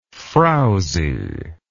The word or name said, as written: frouzy